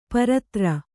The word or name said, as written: ಪರತ್ರ